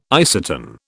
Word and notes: isatin: British English

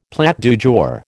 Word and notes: plat du jour: American English

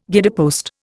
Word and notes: guidepost: Indian

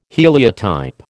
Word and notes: heliotype: American English

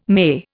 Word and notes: May: Indian

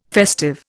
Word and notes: festive: Indian

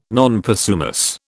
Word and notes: non possumus: British English

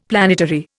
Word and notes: planetary: Indian

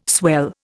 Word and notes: swell: Indian

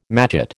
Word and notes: matchet: American English

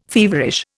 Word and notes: feverish: Indian

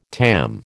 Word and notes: TAM: American English

